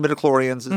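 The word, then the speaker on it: midi-chlorians: Because we're not mentioning midichlorians.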